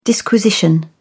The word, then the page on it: disquisition: British English